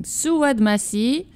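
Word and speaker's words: Souad Massi: That was Sawad. No, wait, I'm mispronouncing. Sawad Massi, Le Bien et le Mal.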